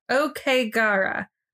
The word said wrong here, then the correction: Aokigahara: I thought it was Aokigahara. – Aokigahara. Okay. So one of the news things I watched was Aokigahara.